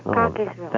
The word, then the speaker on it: Cockeysville: area, and it's not pronounced Cockeysville. It's pronounced Cockeysville. – Cockeysville? – Yeah, C-O-C-K-E-Y-S. Cockeysville.